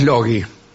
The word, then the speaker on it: Logi: En realidad es Logi, estoy pensando, por cómo se pronuncian las cosas en este idioma.